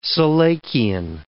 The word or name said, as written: selachian